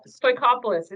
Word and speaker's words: Stoikopoulos: Uh, Stoikopoulos. It's very similar to Workopolis, so we're good.